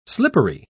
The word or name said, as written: slippery